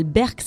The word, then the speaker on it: Berq: Il s'appelle Berk, ça s'écrit B-E-R-Q.